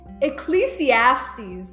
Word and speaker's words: Ecclesiastes: Ecclesiastes. That's how you say it.